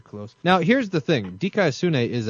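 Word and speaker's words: Dikaiosune (δικαιοσύνη): You do it with proper pronunciation, too, like you've just gotten back from Greece. Dikaiosune.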